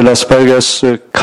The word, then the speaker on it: 라스베이거스: 라스베이거스 그렇게 발음해야 된대요. 사막 가운데 있는 관광과 도박의 도시입니다. 카지노가 많겠죠. 근데 라스베이거스 카지노에 세 가지가 없다라고 합니다.